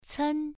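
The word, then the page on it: cen: tone 1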